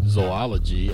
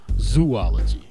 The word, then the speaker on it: zoology: I also don't mispronounce the word zoology as zoology. That's a tricky one. Since there's only three O's in the word and not four, type it in your browser.